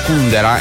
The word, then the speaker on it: Kundera: questa insostenibile leggerezza dell'estero, ecco l'Antonello Venditti qui su Radio Latte Miele, cita anche Milan Kundera e sulla U l'accento. Corretto, perché negli anni 80 tutti quelli che volevano fare un po' i fighi leggevano l'insostenibile leggerezza dell'ester di Milan Kundera.